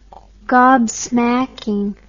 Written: gobsmacking

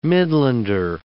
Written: Midlander

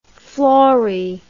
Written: flori

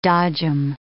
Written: dodgem

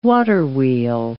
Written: waterwheel

